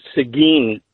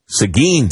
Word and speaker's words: Seguin: The little town is actually pronounced Seguin, Texas. – Seguin, Texas. And yep.